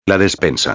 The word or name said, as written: la despensa